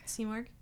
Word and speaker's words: Simurgh: Simurgh.